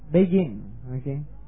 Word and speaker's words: Begin: One of the things that Begin, alright, however they pronounce his name. How do you pronounce it? Begin, okay. And he brought a symbol of salt, a covenant of peace.